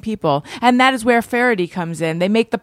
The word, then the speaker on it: Faherty: It's not spelled like you think, or it's pronounced differently than you'd expect from the spelling, but it is pronounced Faraday, and it is spelled F-A-H-E-R-T-Y, and I'm making a point of this so that you go to the right site.